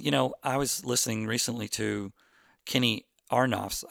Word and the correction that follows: Aronoff: I say Arnoff, I think he pronounces it Aronoff, Kenny Aronoff,